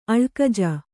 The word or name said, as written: ಅಳ್ಕಜ